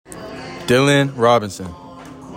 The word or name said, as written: DYLAN ROBINSON